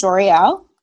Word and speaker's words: Joriel: My name is Joriel. That is pronounced like L'Oreal the makeup, but with a J.